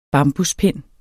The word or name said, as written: bambuspind